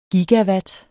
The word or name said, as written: gigawatt